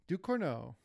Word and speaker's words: Ducournau: I'm struggling with pronunciation again. – Ducournau. – Julia Ducournau.